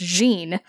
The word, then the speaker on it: Jin: I really liked we've determined that his name is pronounced Gene, which yes. – I was saying Jin the whole time. – When I read it, I was like, "Oh, it's spelled J-I-N." But the narrator, my ever lovely Grover, says it like Gene, G-E-N-E.